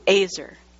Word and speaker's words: ezer: It's spelled E-Z-E-R, but that E has an A sound, Azer.